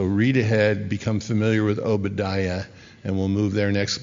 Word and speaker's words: Obadiah: Obadiah, that's how you say it, unless you're in Israel.